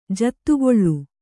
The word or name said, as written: ಜಾದುಕಲ್